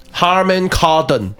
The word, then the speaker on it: Harman Kardon: 我帮大家念一下，它叫 Harman Harman Kardon 的蓝牙喇叭，